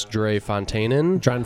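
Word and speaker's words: Drie Fonteinen: Ryan opened up his Dre Fontaine. Did I say that right? – I think it's Dre Fontaine.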